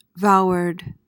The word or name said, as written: vaward